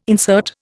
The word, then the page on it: insert: Indian